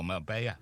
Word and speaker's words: Marbella: Echo special report reports that Spanish police thought wanted criminal Philip Foster was in Marbella, or Marbella, you pronounce it, don't you? That's how you pronounce it.